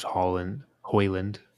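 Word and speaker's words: Haaland: I don't know if they want to do like a Rashford on the left, Hoyland, as I believe it's pronounced.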